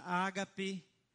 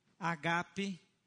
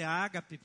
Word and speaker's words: agape: Uma das palavras para amor é a palavra ágape ou agape ou agapos ro. Em português, as pessoas gostam de dizer ágape porque se tornou popular com o acento na sílaba errada.